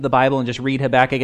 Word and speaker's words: Habakkuk: So our reading today is from the prophet Habakkuk, and Gene did a great job pronouncing that name, Habakkuk, so good job, Gene.